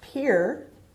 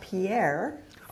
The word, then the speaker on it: Pierre: And I recently visited South Dakota, the state capital of South Dakota, which I've also learned is pronounced Pierre, not Pierre.